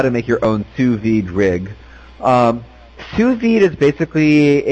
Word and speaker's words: sous vide: Well, how's that actually pronounced, sous vide rig? – Yeah, sous vide.